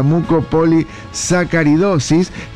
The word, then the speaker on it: Mucopolisacaridosis: El Día Internacional de la Mucopolisacaridosis. Lo dije bien, así es. Día Internacional de la Mucopolisacaridosis.